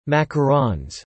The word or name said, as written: macarons